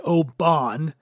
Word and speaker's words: Oban: Oban 14, O-B-A-N. A lot of people say Oban. It's not. It's pronounced Oban.